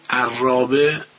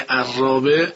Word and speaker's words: عرابه: اما نکته‌ای که باز در مورد حرف را عرض میکنم، ما در تلفظ دو کلمه در زبان فارسی، یکی عرابه یکی آرا، ما دو تا را ادا میکنیم. هنگام گفتن کلمه عرابه دقیقا از نقطه‌ای که حرف را ترقیق میشه حرف را رو تلفظ میکنیم. اما در موقع گفتن حرف کلمه آراء دیگه از نقطه‌ای ناخودآگاه حرف را رو تلفظ میکنیم که نقطه ایست که از اونجا میشه تفخیم کرد.